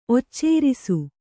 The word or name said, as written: ಒಚ್ಚೇರಿಸು